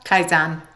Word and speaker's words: Kaizen: Kaizen, yeah.